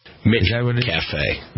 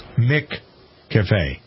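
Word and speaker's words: McCafé: They're giving away, if Roberta Fasanelli, our Queen of Cheap, were here, she'd be all over this, a free small, and you, I didn't pronounce this right, it's McCafe, right? – McCafe. – McCafe? – Just like McDonald's. – McCafe.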